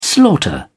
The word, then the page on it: slaughter: British English